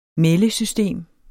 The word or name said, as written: meldesystem